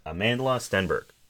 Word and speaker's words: Amandla Stenberg: Let's see, it is Amandla Stenberg.